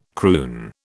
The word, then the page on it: croon: British English